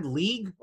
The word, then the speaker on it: league: He said league, like the word leg. Like, I thought he was talking about an appendage. Did you notice that? Like, he's like, yeah, coaches in our leg. And I was like, what did you say? Like, there's other syllables in that, pal.